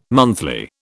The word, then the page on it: monthly: British English